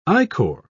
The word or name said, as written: ichor